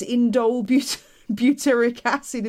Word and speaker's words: indole butyric acid: And what is indole butyric acid in the first place? – Well, good. You did good. You said it correctly.